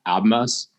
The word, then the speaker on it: Abmas: I have a nugget about your guy, Max Abmas. – The B is silent.